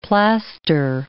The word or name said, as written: plaster